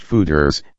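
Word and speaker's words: foeder: The brewery has standard stainless steel fermenters, but it also is set up to ferment beer in 5 US-made oak feeders, pronounced fooders, and one made in France.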